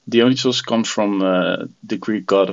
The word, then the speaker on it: Dionisos: Let's talk about your journey as Le Dionisos, which I butchered the name of in the intro. So Dionysos, hopefully I got that one right. – Perfect.